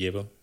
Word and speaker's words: Yevvo: Ja, det er en app, hvis navn jeg nærmest næsten ikke kan udtale. Jibo, vil jeg sige. – Jeg kalder den Jebo. – Jebo? – Ja. – Okay, det kan jeg også sige. Det staves Y-E-V-V-O.